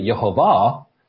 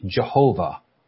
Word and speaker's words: Jehovah: And in this form of Yiddish, the Ashkenazi took the yod and made it a Y sound. There's another set of Jews, the Sephardic Jews, who here, they apparently retained the pronunciation of J for the yod sound. So rather than Yehovah, the argument is it should be Jehovah, because yod should make the J sound because of these Sephardic Jews.